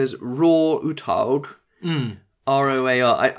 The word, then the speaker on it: Roar: But the thing is that he's Norwegian, so I imagine it's pronounced something like Roar. – Roar.